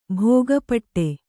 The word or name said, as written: ಭೋಗಪಟ್ಟೆ